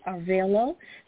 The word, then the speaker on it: Arevalo: And her name is Cindy Arevalo.